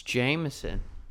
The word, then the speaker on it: JMSN: He spells his name JMSN, but that's not how he pronounces it. So why don't you help us out? – It's Jameson.